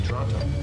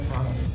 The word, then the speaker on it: Toronto: Toronto. – Toronto. – Need no pronounce the T's.